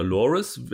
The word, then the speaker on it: Laris: Und das sind Laris und, oder Loris wird es ausgesprochen, aber geschrieben Laris und Chavan, Z-H-A-B-A-N geschrieben.